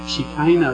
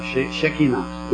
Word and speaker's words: Shekinah: And I found out that I can no longer actually say Shekinah, as I've done for 50 years, but I have to say now Shekinah, which is the Hebrew pronunciation I gather.